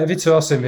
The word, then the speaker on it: Ivica Osim: You wanted to talk about um, Wilson Ivica Osim. Have I pronounced that correctly? – Yeah, Ivica Osim, who, who, who died yesterday.